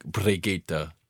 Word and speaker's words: Brigitte: I can't roll my R's, but it's supposed to be like Brigitte.